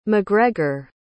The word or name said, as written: macgregor